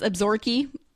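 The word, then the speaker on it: Absaroka: Absorkey. That's a good one. – Oh, yeah. – Yeah. Because it's spelled Absaroke.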